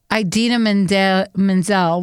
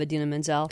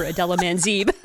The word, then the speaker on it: Idina Menzel: Yeah, everyone can pronounce her name right except for John Travolta, who called her Adela Manzeeb. – It was so off.